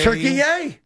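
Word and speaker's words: Türkiye: T-U-R-K. – Yeah, that's Turkey. Yay! – T-U-R-K-I-Y-E. – Turkey. Yay! And that's how you have to pronounce it now, too.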